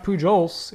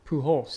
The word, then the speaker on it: Pujols: Do you actually to pop pronounce the J in Pujols? I think it's just, you don't say Pujols. – Portals? – It's not Pujols, it's Pujols. So that Pujols would be Portholes.